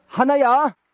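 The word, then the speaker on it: 하나냐: 그때 하나니 여러분 그 말을 잘 발음해 보십시오. 하나야.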